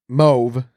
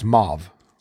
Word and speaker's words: mauve: So mauve is mauve. Yeah, mauve. It once rhymed with stove, but now the AU is son of. So it's mauve.